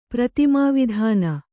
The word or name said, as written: ಪ್ರತಿಮಾವಿಧಾನ